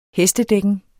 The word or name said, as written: hestedækken